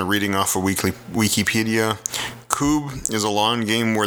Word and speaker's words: Kubb: It's called Koob. It's spelled K-U-B-B.